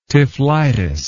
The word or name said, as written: typhlitis